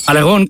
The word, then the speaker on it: Aragón: Aragón con acento en la O.